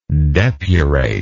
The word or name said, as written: depurate